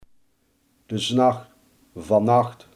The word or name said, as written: vannacht